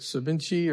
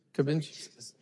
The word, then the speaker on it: ceviche: One brother wanted me to try some of his, how you say it, ceviche or cavinche? Huh? – Ceviche.